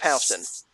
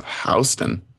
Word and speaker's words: Houston: Because people in New York don't know how to pronounce Houston. – Oh. They really pronounce Houston as Houston? – Well, the Houston, Houston is the city. Houston is the street.